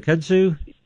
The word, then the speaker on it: kudzu: I think it's called a kurdus or something like that. – The vine, the kudzu? – Yeah. – Yep. – How do you pronounce that? – Kudzu, K-U-D-Z-U. Yep.